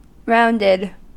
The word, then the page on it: rounded: American English